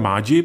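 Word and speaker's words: Maggi: Baltazar Mádži, pokud se nepletu, tak eh, s touto výslovností, tak eh, zkrátka Italové uprostřed čumaské přírody chtěli postavit to, co je v Itálii.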